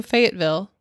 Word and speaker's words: Fayetteville: Next, a bus driver comes forward and says he was driving through Fayetteville.